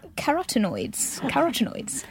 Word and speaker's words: carotenoids: Carotenoids.